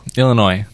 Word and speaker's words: Illinois: Illinois. – Dang it. – That's got to be French as well. – Probably. Silent S, that's how you know.